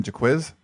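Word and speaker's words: Jacquizz: Yeah, well, Jaquiz, that's how you say his name, Jaquiz? – Jaquiz, interesting.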